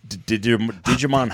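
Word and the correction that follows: Djimon: Hatsu is in it. – Oh, thank you. Jaimon Hatsu. – Huh? – Jaimon. – Yeah, yeah, what I said. The D is silent.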